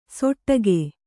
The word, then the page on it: ಸೊಟ್ಟಗೆ: soṭṭage